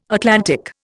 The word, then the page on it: Atlantic: Indian